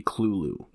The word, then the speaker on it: Cthulhu: The correct or the closest pronunciation would be Clulu.